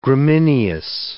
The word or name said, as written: gramineous